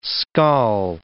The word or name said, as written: scall